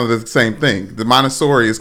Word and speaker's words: Montessori: is in a Montessori school.